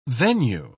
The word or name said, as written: venue